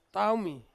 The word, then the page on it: taumi: Ansus